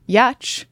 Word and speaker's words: Giertz: I mean, granted, my ancestors decided that my last name spelled G-I-E-R-T-Z was going to be pronounced Yatch.